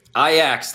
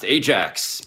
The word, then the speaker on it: Ajax: Ajax, that is how people who know soccer pronounced Ajax.